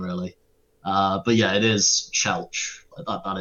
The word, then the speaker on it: Chelch: He said Kletch, like, there's no T in it, there's no N in it.